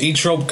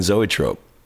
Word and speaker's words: Zoetrope: Wait, are you telling me that the film company is not pronounced e trope? – No, it's Amer- it's pronounced American zoetrope.